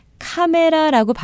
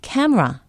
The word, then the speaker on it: camera: 카메라라고 발음하시는 분들 많죠? 미국 사람들은 못 알아듣습니다. C-A-M-E-R-A, camera, camera.